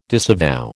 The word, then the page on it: disavow: American English